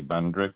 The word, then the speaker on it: Bundrick: Dewey Bendrick. – Thank you. Could you please spell that last name? – B-U-N-D-R-I-C-K.